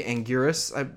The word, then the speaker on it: Anguirus: Pull some of these from that were, like, my boy Anguirus, I believe is how you pronounce it.